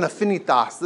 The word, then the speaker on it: affinitas: There's a little bit of subtle, you know, this is an affinitas. This is, and you got to get the ta, okay? You got to say it right.